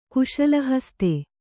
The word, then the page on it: ಕುಶಲಹಸ್ತೆ: kuśala haste